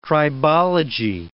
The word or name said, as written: tribology